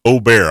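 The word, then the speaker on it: Åberg: It's pronounced Ober. I learned that today while watching the coverage.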